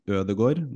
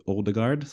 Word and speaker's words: Ødegaard: And there's also another guy named Ødegaard, not Odegaard.